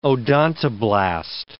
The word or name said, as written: odontoblast